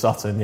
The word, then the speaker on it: Sutton: For the sake of our professional relationship, I'll confirm that, yeah, it's just Sutton, yeah.